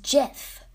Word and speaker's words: Geoff: Except it's spelled like Geoff, but pronounced Jeff.